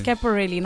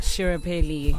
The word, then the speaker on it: Schiaparelli: Yes, it's Schiaparelli, not Shirapelli.